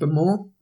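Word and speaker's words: Bowmore: I think it's pronounced Bemore.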